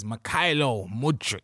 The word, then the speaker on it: Mykhailo Mudryk: His name is Mykhailo Mudryk.